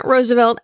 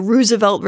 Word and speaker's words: Roosevelt: And the same last name, although it's pronounced differently in the different sides of the family. But yeah. – Yeah, Roosevelt versus Roosevelt. – Roosevelt, right.